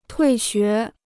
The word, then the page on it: 退学: tuì xué